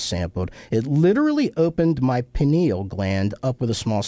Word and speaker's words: pineal: Am I pronouncing that right? Pineal? Pineal gland? – Yeah.